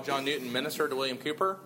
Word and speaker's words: Cowper: He did a talk a couple years back on William Cooper. It looks like Cowper, but it's pronounced Cooper.